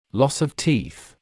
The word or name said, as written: loss of teeth